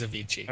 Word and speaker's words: Avicii: Is that how you say it? Avicii? – I don't know. – Avicii. It is Avicii.